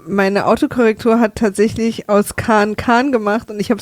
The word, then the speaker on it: Khan: Muss man eigentlich immer dazu sagen, weil der Name wird natürlich korrekt Khan ausgesprochen. – Ja, das stimmt. Ja, also, und nicht wie Oli Khan, sondern H und A andersrum.